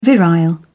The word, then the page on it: virile: British English